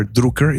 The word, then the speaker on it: Drucker: But Peter Drucker, or pronounced Peter Drucker is the right pronunciation.